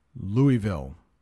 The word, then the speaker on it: Louisville: Kentucky is famous for its horse racing, and the Kentucky Derby is held annually in Louisville. That's how you pronounce that. Louisville. Let's talk about 2 very difficult words here.